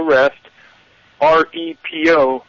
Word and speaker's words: Restrepo: It's in a clip, or actually a movie that Dutch has, and the name of it is Restrepo. You spell that R-E-S-T, like to take a rest, R-E-P-O, Restrepo, which was the name of a medic, a very brave medic who was shot to death in Afghanistan.